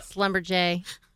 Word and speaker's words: Schlumberger: It's not even spelled the way it sounds either. – What? – It's spelled Schlumberger. – Yeah. – Yeah. – But it's called Slumberjay.